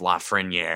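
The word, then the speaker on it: Lafrenière: Which I know it's technically pronounced Lafreniere.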